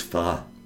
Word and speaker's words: pho: It's pho. – P-H-O? – P-H-O.